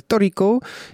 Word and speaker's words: Toriko: Et ça s'écrit comme un tricot, voilà, euh, comme, un tricot qu'on tricote. Donc le, groupe s'appelle Toriko, et le morceau s'appelait Tokyo Vampire Hotel, mais ils trichent, ils viennent pas de Tokyo, ils viennent de Kyoto.